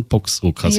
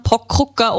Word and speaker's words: Poxrucker: Ganz viele Menschen, also auch in Oberösterreich, haben das Problem mit dem X. Also das viele sagen dann Pockrucker oder erfinden irgendwas.